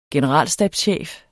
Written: generalstabschef

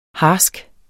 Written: harsk

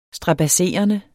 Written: strabadserende

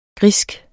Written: grisk

